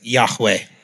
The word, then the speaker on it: Yahweh: Every time you see that word "the Lord" in the Old Testament, the actual written text is what we call Yahweh now. It's our best attempt at understanding what that would have sounded like, how it would have been pronounced. It's like Yahweh.